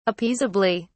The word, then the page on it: appeasably: British English